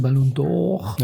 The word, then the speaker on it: Ballon d'Or: wie wir Franzosen sagen, Ballon d'Or. Ja. Richtig ausgesprochen, weiß nicht. das ist Französisch.